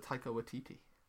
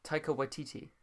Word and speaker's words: Taika Waititi: Taika Waititi. – Taika Waititi.